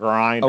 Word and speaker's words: Grindr: Florida man Jamel Moore is charged with armed robbery and attempted first-degree murder after he met his date on the website or app called Grindr, it's pronounced. – Grindr. – Okay, Grindr.